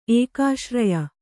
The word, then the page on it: ಏಕಾಶ್ರಯ: ēkāśraya